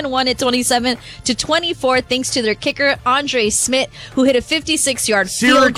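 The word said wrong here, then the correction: Szmyt: Syracuse legend, Andre Schmidt. – Oh, my bad. – Legend. – Is it Schmidt? – Schmidt, yes. It's spelled S-Z-Y-M-Y. Yeah, I feel like I've heard Schmidt.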